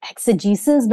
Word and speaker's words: exegesis: And then also just reading a lot of, I think it's called like exegesis, but I don't think I've ever said that word out loud, Cameron. – I think you're right. That's how it's pronounced for sure.